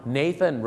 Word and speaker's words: Nathan Road: Nathan